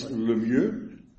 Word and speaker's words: Lemieux: Mr. Is it Lemieux? – It's good enough, Your Honor. – Well, tell me how to say it correctly. – It's Lemieux, but okay. The sounds aren't in American English.